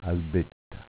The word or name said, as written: Albetta